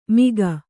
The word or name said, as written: ಮಿಗ